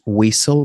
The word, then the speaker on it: whistle: Eh, la pronunciación correcta es whistle, blow the whistle,